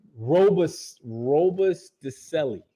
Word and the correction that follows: Robustelli: You know the Andy Robusticelli. I think he was a Hall of Famer. – Robustelli? Robustelli. – Robustelli. Yeah, he got it.